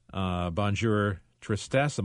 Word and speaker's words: Bonjour Tristesse: Anyway, she, again, as you mentioned, does a second movie with Otto Preminger, Bonjour Tristesse.